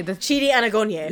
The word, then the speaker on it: Chidi Anagonye: Chidi Anagonye. – Yeah. Ooh. Dude, you did such a good job pronouncing that.